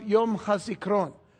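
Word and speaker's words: Yom HaSikron (יום הזיכרון): And I believe it's pronounced Yom HaSikron, not with an A, but it's in there, but it's not pronounced. Yom HaSikaron, the day of remembrance.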